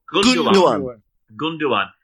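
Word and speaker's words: Gündoğan: Is that how you say it? Gundogan? And Gundogan.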